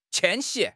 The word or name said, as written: 迁徙